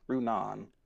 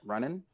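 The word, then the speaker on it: Runaan: But his name is pronounced Runan. In my review, I kept pronouncing it running. Like it's kept saying running, and so many people commented like It's not running like he's jogging. It's Runan.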